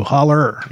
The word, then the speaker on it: hollow: Holler. – Yeah. But it would have to be, it would just be spelled hollow. It would be pronounced holler.